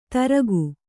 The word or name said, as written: ದರಕು